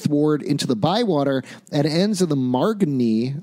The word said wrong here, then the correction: Marigny: I'm probably pronouncing that wrong. – Yeah, Marigny. Marigny. Oh, that makes more sense.